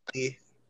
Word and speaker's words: Revolori: He's Tony really, Revolori.